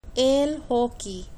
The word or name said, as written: el hockey